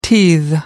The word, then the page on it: teethe: American English